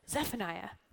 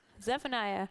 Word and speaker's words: Zephaniah: And you know you're saying it right if it sounds kind of like a sneeze. Zephaniah. Zephaniah chapter 3, verse 17.